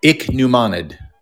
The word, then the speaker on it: Ichneumonid: Ichneumonid. – Ichneumonid. Okay. – It's a German word, so you're supposed to be real throaty. Ichneumonid. – Ichneumonid. – Ooh, yeah, just ichneumonid.